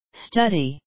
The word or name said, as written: study